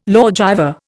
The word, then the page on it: lawgiver: Indian